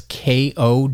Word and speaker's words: Ko-fi: The two other big options I see very, very regularly now is a site called Ko-fi. That's K-O-F-I. Their social media says it rhymes with no fee, so Ko-fi.